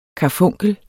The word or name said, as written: KARFUNKLERNE